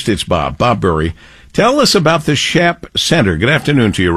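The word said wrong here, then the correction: Schaap: Well, the first thing, I'm going to correct the pronunciation. It's pronounced like it would be spelled S-K-O-P, SCOP.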